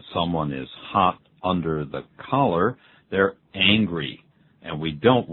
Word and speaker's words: collar: C-O-L-L-A-R. C-O-L-L-A-R. Collar. – 也就是衣领哈。在衣领下它整个发热的, 就是那个热气快爆出来, 快发火的意思啊。我们用 collar.